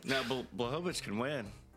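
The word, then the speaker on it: Błachowicz: Blahovic. – There you go. Ugh. K silent. – Oh, gross. There is no K. – See, so if you just remember K silent, you'll pronounce it correctly.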